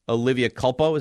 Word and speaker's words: Olivia Culpo: Olivia Culpo, is that how you say her name? – Yeah.